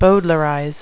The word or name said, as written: bowdlerize